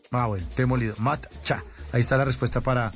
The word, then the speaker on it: matcha: El, macha es esa opción. Se escribe matcha, José Puentes. Eh, se escribe matcha. Mat-cha, con che.